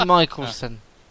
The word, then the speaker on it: Mikkelsen: It's all Lars Mikkelsen. – Thank you. It's actually Mikkelsen.